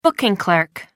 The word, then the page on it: booking clerk: American English